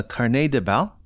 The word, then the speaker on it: Carnet de Bal: And tell me if I'm saying this correctly or otherwise. It's called a, uh, a Carnet de Bal.